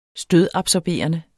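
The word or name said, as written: stødabsorberende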